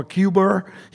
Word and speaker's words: Cuba: And when he almost went to war over Cuba, he pronounced it Q-B-E-R, Cuba, as a good Massachusetts man.